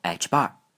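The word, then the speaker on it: ħ: 调一下，康普顿波长中的 h bar 为约化普朗克常数，它和普朗克常数 h 的关系是 h 等于二派 h bar。后面发现普朗克常数经常要和角度量一起计算，要经常抵消二派，所以为了简洁也不浪费笔墨，就在 h 上加了一条横杠，读作 h bar。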